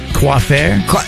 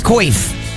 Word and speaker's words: coif: Oh, coiffure? – Coiffure? It's C-O-I-F. How do you pronounce it? This is West Virginia. It's coif.